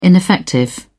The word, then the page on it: ineffective: British English